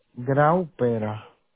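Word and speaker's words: Graupera: Grauperra. – Grauperra. Okay. That's a unique last name. – It is very unique, actually. If I find another Grauperra, we're probably related.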